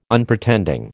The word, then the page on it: Unpretending: American English